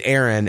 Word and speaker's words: Aaron: Like my first name is Aaron and I pronounce like that air, like that you breathe. And then like un, like you in, you know, that's more or less the way I pronounce.